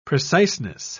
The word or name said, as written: preciseness